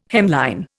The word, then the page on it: hemline: Indian